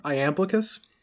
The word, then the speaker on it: Iamblichus: Iamblichus? – Uh-huh. Yeah. – Oh, marvelous. Okay. Because Iamblichus, it's a name, it floats around, I see it a whole bunch, but my primary sort of touchstone for Iamblichus